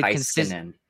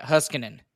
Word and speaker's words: Heiskanen: I remember NBC one time calling it Huskinen. I heard Huskinen.